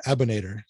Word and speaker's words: Abinader: I've been mispronouncing it. It's like Terminator. It's easier to put out that way. It's like Abinader.